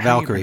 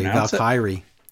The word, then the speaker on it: Valkyrie: And I love the way that Carl Hawley says, like, Valkyrie. What is she saying? – Valkyrie. Not Valkyrie, Valkyrie. Yes.